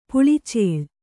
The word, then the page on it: ಪಲ್ಲಚೀಲ: palla cīla